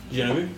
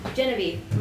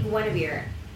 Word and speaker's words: Genevieve: How is your name, your character's name pronounced? – Genevieve. – Genevieve. My brain keeps telling me Guinevere. Genevieve.